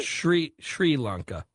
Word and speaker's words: Sri Lanka: Sri Lanka.